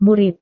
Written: murid

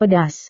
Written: pedas

